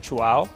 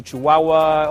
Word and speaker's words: Chuao: And to help them say it correctly, we tell them to think that you chew and then you say wow, chew, wow. And if it's not a wow, it's not a chihuahua.